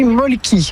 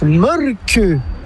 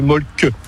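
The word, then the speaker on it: Mölkky: Ça s'écrit molki, mais ça se prononce molke. – Le molke. – Plutôt un E qu'un E, mais pas un U. Donc ça fait molke.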